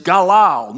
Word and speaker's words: galal: And then also the word roll away here is galal, much like Gilgal. It rhymes with Gilgal, and it means roll away.